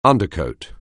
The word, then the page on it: undercoat: anglais